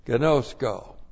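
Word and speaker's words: gnosko (γινώσκω): Agapao and gnosko, G-I-N long O-S-K long O, gnosko.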